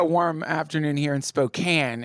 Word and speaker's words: Spokane: It's Spokane.